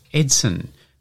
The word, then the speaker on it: Edson: Well, it was because electricity was introduced to his hometown when he was born, so hence Edson, which is Portuguese for Edison and is pronounced Edso.